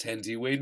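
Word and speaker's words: Thandiwe: and according to Wikipedia, is now pronounced Tandiway Newton.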